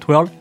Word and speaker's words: Twirl: faut le prononcer, euh, TW, un peu phonétique TWURL, euh, donc Twirl.